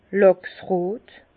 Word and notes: làchsrot: Bas-Rhin; Herrlisheim

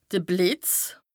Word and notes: de Blìtz: Bas-Rhin; Strasbourg

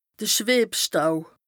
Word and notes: de Schwëbstaub: Bas Rhin

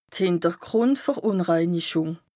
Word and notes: d' Hìntergrùndverùnreinichùng: Bas Rhin

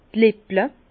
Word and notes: d' Lippel: Bas-Rhin; Herrlisheim

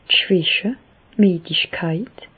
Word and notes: d' Schwäche, d' Miedigkeit: Alsatian; Bas-Rhin; Reichshoffen

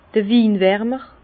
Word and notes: d'r Winwärmer: Bas-Rhin; Herrlisheim

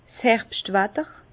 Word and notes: 's Herbschtwatter: Bas Rhin; Herrlisheim

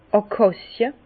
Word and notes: d' Akàcie: Bas Rhin; Herrlisheim

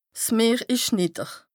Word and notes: 's Meer ìsch nìdder: Bas-Rhin; Strasbourg

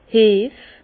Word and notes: 's Heff: Alsatian; Bas-Rhin; Herrlisheim

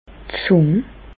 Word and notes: d' Zùng: Bas-Rhin; Reichshoffen

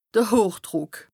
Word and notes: de Hochdrùck: Bas-Rhin; Strasbourg

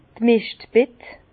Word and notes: d' Meschtbett: Bas-Rhin; Herrlisheim

